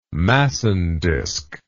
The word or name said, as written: massondisk